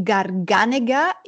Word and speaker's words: Garganega: But the grape, and I always mispronounce it, Gargenia. I was wondering if you could explain a little bit about what that grape means and what that grape should taste like. – The Garganega is our...